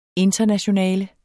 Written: internationale

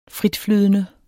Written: fritflydende

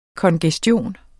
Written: kongestion

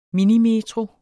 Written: minimetro

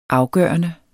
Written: afgørende

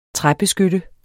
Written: træbeskytte

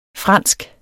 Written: fransk

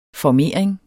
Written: formering

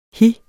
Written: hi

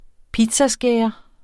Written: pizzaskærer

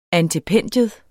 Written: antependium

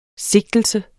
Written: sigtelse

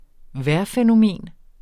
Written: vejrfænomen

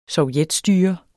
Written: sovjetstyre

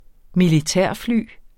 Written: militærfly

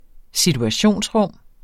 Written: situationsrum

